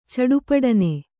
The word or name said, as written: ಚಡಪುಡನೆ